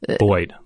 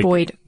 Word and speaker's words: boid: And you've heard of Boyd technology before too, which is sort of similar in that, Boyd. – Like, Boyd. – This is B-O-I-D? – B-O-I-D, which I guess is sort of a Boyd pronunciation of bird, right? – Yeah.